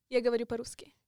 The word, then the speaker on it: Я говорю по-русски: How do you say it? – Я говорю по-русски. – Я говорю по-русски. – Yes.